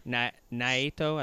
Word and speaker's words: Naito: He is facing the IWGP Intercontinental Champion, Tetsuya Naito. Naito, I think that's the Japanese pronunciation.